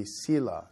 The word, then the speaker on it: Selah: Probably Hebrew pronunciation is close to that, but most English translation pronunciation will be Selah.